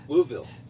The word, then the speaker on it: Louisville: So, did I say that correctly? – No. – Oh. – Louisville. – It's like one giant, like, Louisville. – Well, that's the same thing. You got to be from here to say it like that.